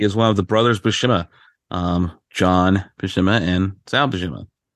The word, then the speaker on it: Buscema: Buscema.